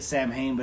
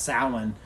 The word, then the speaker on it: Samhain: You see it Samhain, but it's pronounced Samhain.